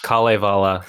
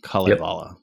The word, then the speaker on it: Kalevala: So it's the Kalevala. So it's just each syllable or each two letters is its own syllable. – Oh, I can do this now. Kalevala.